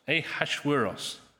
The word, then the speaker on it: Ahasuerus: The Hebrew name, which we had read, was Ahasuerus. Ahasuerus. Apparently that's how it's pronounced.